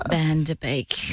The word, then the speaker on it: Van Der Beek: James Van Peek. – No. – Get the name right. The correction was an incorrect name. – Wow. – Right person and then pronounce the name wrong. – James Van Der Beek.